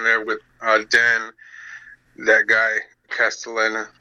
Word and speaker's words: Castellaneta: Oh, Dan Castellaneta, I mean, yeah.